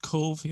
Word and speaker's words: Cobh: And so I know one of my relatives came, and I hope I'm pronouncing, is it Cove? Am I pronouncing that correctly? – That's it, Cove, yeah. – Yeah, but spelled C-O-B-H.